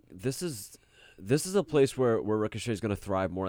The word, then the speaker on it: Ricochet: Ricochet, yeah.